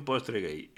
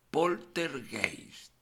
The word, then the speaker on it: poltergeist: Pero que tú fíjate que si lo pronuncias bien, da más miedo todavía poltergeist.